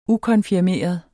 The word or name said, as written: ukonfirmeret